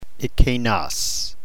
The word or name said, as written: ekeinos